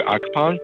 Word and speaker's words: Akhathong: Andrew Akhathong.